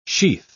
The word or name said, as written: sheath